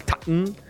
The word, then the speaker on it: Tottenham: La pronunciación correcta del TAN.